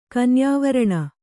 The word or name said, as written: ಕನ್ಯಾವರಣ